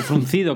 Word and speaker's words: fruncido: Tengo el ceño frungido. O fruncido, ¿cómo se dice? ¿Frungido o fruncido? Luego lo busco. Fruncido. Bueno, fruncido, tengo el ceño fruncido.